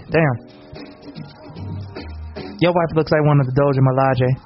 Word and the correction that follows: Dora Milaje: I don't even think I said that right. – Dora Milaje? – Yeah, what you said.